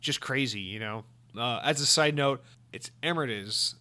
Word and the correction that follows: amortize: which is gradually write off the initial cost. So the word is amortize. So I was definitely pronouncing that wrong.